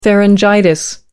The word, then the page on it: pharyngitis: American English